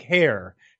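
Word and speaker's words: Hehir: I know the man, and I didn't know how to pronounce his last name. But having heard it now a million times on podcasts, it's like Hayhier.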